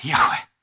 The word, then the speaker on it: Yahweh: so it would often be pronounced Yahweh, Yahweh, which means breath, right?